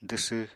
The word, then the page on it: dwsw: apatani